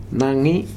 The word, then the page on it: Nagi: Futuna